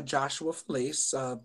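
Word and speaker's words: Josué Falaise: Yes, my name is Joshua Felice, but it's spelled in French, Josué. Falaise. So that's one thing people don't realize.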